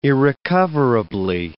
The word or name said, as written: irrecoverably